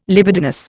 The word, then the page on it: libidinous: Indian